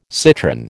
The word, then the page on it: citrin: American English